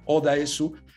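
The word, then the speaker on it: Oh Dae-su: De hecho, Park Chung-wook dijo en una entrevista que Oda Esu, el nombre que eligió es fonéticamente Odipo, como lo pronuncian en Corea del Sur, ¿no?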